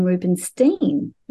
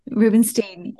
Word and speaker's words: Rubenstein: I'm sitting in Canberra, and Jacqueline, I should just correct you that I pronounce my name Rubenstein. Rubenstein.